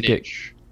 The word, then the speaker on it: Ng: So I like this a lot from Kim NG. Is it Nige? How do you pronounce it? I always Nige. – Nige.